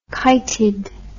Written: kited

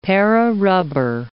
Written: Para rubber

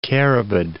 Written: carabid

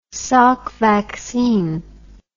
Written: salk vaccine

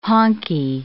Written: honkie

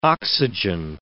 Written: oxygen

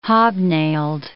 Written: hobnailed

